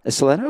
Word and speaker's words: Ysleta: Did I say that right? Ysleta. Ysleta, okay.